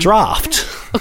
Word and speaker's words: draught: Wait, how do you say it then? – Draft. – Okay, so what is draft? – Like there is a draft in the house. – See, I keep looking at it like the D-R-A-U-G-H-T.